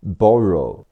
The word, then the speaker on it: borrow: as as soon as as soon as as soon as 动词给递走过通过 pass p a s s pass p a 发 p a pa 两个 s 发 s pass 美式发音是 pass p a p pass 动词借借用 borrow b o 发 b o b r o w 读 r o row borrow，美式发音是 b a b borrow，动词借给借出，lend，l e n d，lend，l e 读 l e l，加上 n，lend 加上的 lend，lend，过去式是 lent，lent，名词手指 finger，f i n g e r，finger，f i 读 f e f，加上 n，fin。